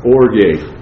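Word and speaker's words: orge (ὀργή): When you say the word wrath, in the Greek it has a hard G in it. In English it's a soft G. The word in Greek is orge. In English it's orgy.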